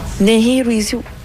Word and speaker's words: Nehirawisi: Comment ça se prononce? – Il dit Nehirawisi. Nehirawisi.